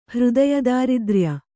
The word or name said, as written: ಹೃದಯದಾರಿದ್ರ್ಯ